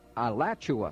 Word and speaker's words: Alachua: Alucha, I'll spell that, A-L-A-C-H-U-A, I guess it's Alachua, sorry, thank you, Florida 32615.